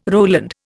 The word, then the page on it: Roland: Indian